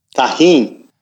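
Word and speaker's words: Tajín: How do you say it? Tajin? Tajin? – Tajin. – I knew it. I knew it was a soft J.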